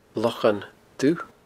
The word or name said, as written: Locha Dubh